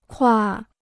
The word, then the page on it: 挎: kuà